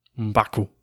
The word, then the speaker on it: M'Baku: I think I said that right. – M'Baku. Yep. And he did great in that.